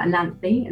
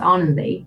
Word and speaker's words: Ananthi: it's spelled Ananthi, and that's how English people would say it, but it's pronounced Arnanthi, but no one could say it.